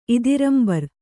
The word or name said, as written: ಇದಿರಂಬರ್